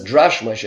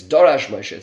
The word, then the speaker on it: Dorash Moshe: The Dorash Moshe says, but some people mispronounce this as Drash Moshe. It's Dorash Moshe.